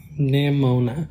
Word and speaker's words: Nimona: Nimona. – Okay, I knew that, but then I almost said pneumonia.